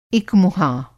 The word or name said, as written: इकमुहां